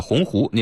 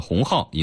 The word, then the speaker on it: 鸿鹄: 在演讲中，林建华将立鸿鹄志中的鸿鹄念成了鸿号，引发广泛关注。